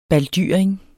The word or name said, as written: baldyring